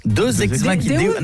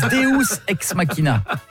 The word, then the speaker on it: Deus ex machina: Deus ex machina. – Ah, parce que deux, j'aurais dit que c'était deux ex que t'appelles machine. – Non, ça se prononce Deus ex machina.